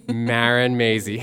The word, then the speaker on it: Marin Mazey: Marin Mazey.